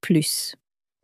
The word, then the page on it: plus: french